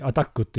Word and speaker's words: ATT&CK: け、あの、小ネタなんだけど、 7 月の 8 日に、これも前に話したネタの、まあ、フォローだけど、アタック、マイターが出してるアタックっていうフレームワーク、 ATT&CK ってやつね。はい。はい、アタックって読むんですけど、これの新しいバージョン 7 ってやつが、えー、満を持してリリースされました。